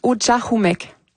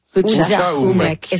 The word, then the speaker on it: Utchahumek: On le prononce Utchahumek. – Utchahumek.